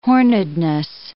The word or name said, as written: hornedness